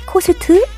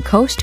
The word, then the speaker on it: coast: Coast. 발음에 주의해주세요. 코스트 아닙니다. 첫 번째. traveled, we've traveled from coast to coast, we've traveled from coast to coast, we've traveled from coast to coast.